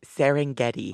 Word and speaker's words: Serengeti: It's Serengeti.